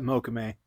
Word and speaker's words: mokume: Yeah, you can just call it mokume. – Okay, mokume. Damn it!